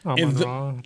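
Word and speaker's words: Amon-Ra: But but Amron was his name. Amron Saint Brown. Saint Brown. – Amon Ra. yeah, Amon Ra.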